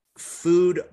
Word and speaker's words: Fud: But it was hosted by Food Rapper, F-U-D, but like with the long U sound.